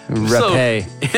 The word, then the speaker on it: rapé: Rape. – Yeah. Rapé. You pronounced that wrong. – Rapier. – It's French.